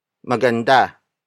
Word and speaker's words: maganda: You can also say buhay na maganda. Buhay na maganda. Buhay na maganda. It also means beautiful life, but with the adjective placed after the noun. Word for word, it's buhay na maganda.